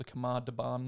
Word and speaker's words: Khamar-Daban: I don't even know that's how you pronounce it. Might be Daban, Khamar-Daban range. – The way you said it's perfect.